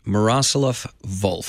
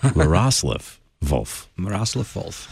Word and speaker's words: Miroslav Volf: Miroslav Volf. – See, I would have put the accent on the wrong syllable. – Miroslav Volf.